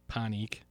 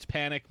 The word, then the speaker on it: Panik: Panik. I know. It's P-A-N-I-K. You would think it's panic, but they pronounce it panik.